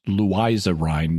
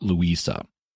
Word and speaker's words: Louisa Rhine: With a book by parapsychologist Louisa Rhine, and yes, it is pronounced Louisa Rhine, not Louisa.